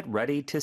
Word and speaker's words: ready: ready D가 모음 소리 사이에 껴서 리을처럼 부드럽게 발음이 되는데요. 그 뒤에 오는 투도 모음 사이에 껴요. 그래서 ready to, ready to 이렇게 리을 발음처럼 두 개를 연이어서 해주시면 됩니다.